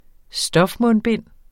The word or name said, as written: stofmundbind